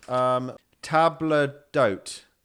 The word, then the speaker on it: table d'hôte: Right, so we're pronouncing this table d'hôte. Table d'hôte. – You got it.